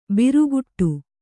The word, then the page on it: ಭ್ರುಕುಟಿ: bhrukuṭi